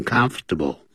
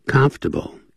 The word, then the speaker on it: comfortable: 实际念的时候是念成 comfortable 三个音节而不是念成四个音节 comfortable in the water 当然，如果你要是念英式的呢，那可能他会念四个音节，他整个就会念成 When you aren't comfortable in the water，那会这样念啊。